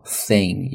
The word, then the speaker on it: thin: 其次呢，这个单词发音的时候呢，在咬舌头 thin 咬舌尖，找一找你的舌尖在哪里。